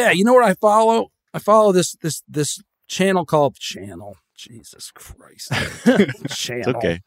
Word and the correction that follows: Chanel: It's actually pronounced Chanel.